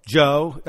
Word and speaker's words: Djo: He goes by DJO. Joe, you just don't pronounce the D.